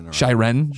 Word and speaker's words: Shiren: Jared Petty from IGN, parentheses, whom I love, by the way, has an article about Shrin the Wanderer. Did I say that right? Shiren, the Wanderer, being the last great Vita game.